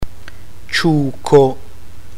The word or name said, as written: ciuco